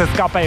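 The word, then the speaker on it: Ska-P: E vorba de Escape, că așa se pronunță Escape Eota.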